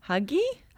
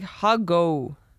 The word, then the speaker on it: hygge: Huggy? – Huggy? – Hi, Gee. Who go? – Pardon? – I read somewhere that it's actually pronounced like huggo. – It might be. But, okay, so the word is H-Y-G-G-E. – H-Y-G-G-E.